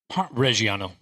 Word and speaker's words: Reggiano: Reggiano.